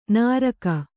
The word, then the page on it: ನುರ್ಗು: nurgu